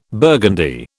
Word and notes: burgundy: British English

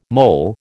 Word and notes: mol: American English

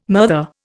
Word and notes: murther: Indian

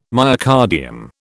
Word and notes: myocardium: British English

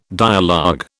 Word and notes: dialogue: British English

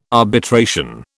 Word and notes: arbitration: British English

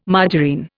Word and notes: margarine: Indian

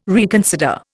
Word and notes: reconsider: Indian

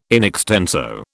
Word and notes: in extenso: British English

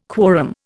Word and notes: quorum: Indian

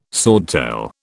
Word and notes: swordtail: British English

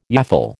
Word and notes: yaffle: American English

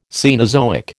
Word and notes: Cenozoic: American English

